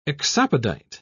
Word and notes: exopodite: British English

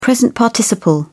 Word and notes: present participle: British English

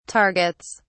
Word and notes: targets: British English

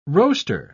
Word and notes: roaster: British English

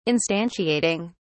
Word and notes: instantiating: British English

